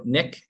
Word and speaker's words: Nick: So Nick, N-I-C-K. And then Process Zip is spelled P-R-O-C-E-S-S and Z-I-P.